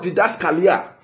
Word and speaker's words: didaskalia: Yeah, that word there, doctrine, is the Greek word didaskalia. Yeah, I'll spell that for you. D-I-D-A-S-K-A-L-I-A.